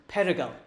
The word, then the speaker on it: perygl: So this is our fourth P, which is danger, which is a D in English, but in Welsh it's perigal, so that counts. Okay, you can write that down. P-E-R-Y-G-L, that's how you spell it in Welsh.